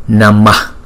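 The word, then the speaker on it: namaḥ: Trong tiếng Phạn, nếu mà chữ H có dấu nặng này, chúng ta sẽ lấy cái nguyên âm ngoài trước, chúng ta thêm vào ngoài sau. Thành thử ra chúng ta đọc là Namaha. Nhưng mà cái chữ A ngoài sau mà chúng ta choàng ra đó, đọc rất nhẹ.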